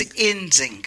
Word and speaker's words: Nzinga: It's Nzinga.